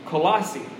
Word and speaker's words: Colossae: Hierapolis and Colossae.